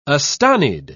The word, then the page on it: astonied: British English